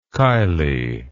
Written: Kiley

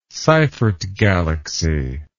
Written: Seyfert galaxy

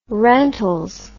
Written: rentals

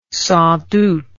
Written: sans doute

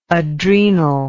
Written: adrenally

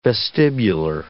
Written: vestibular

